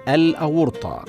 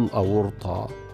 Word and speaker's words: الأورطة: من الكلمات المتصلة بقلب الإنسان شريان الأورطة بفتح الهمزة والطاء وضم الواو هكذا ينطق شريان الأورطة ولكن البعض ينطقه بكسر الطاء وهذا خطأ شائع والصواب نطقه هكذا شريان الأورطة فنقول شريان الأورطة